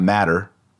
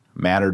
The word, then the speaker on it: Mater: Notice that that first A is not short, it's long because the accent goes on the second to last syllable. You'll get tired of me saying this, but you gotta know it. It's not matter, Mater Dei. No, it's Mater Dei.